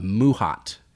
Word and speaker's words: mahout: Basically, what you had to do is you would get this guy, and his name was, I wrote it down so I could say it correctly, phonetically, a Muhat, and it's spelled M-A-H-O-U-T.